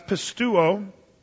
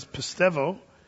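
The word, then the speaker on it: pistevo (πιστεύω): The Greek word for that is pistuo. That's the old pronunciation. The modern is pistevo.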